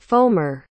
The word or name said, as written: foamer